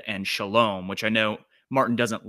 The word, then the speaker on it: Shalom: I don't make the like guttural sounds. So I would also pronounce it Hesed and Shalom.